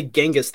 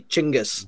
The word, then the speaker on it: Chinggis: they actually pronounce that uh, they don't say Genghis, they they say Chinggis.